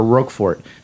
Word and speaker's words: Roquefort: I'm going to blame Das Racist, the rap duo, for my mispronunciation of Roquefort because they use that in, or Roquefort, because they use that in a slant rhyme to rhyme it with the type of shoe, Roquefort's.